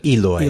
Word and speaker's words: Eloi: How do you guys pronounce it? – Eloi. – Eloi. – Eloi. Okay, good.